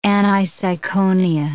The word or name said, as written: aniseikonia